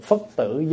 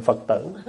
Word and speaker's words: phất tử: Cho người đọc cái câu đó phải đọc là cô hồn phất tử giáng lâm lai, chứ không phải cô hồn Phật tử.